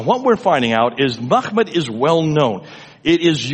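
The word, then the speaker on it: Mahmud (محمود): Mem, mim, ha, mim, dal is Mahmud. That's how you pronounce it.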